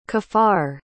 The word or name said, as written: caffar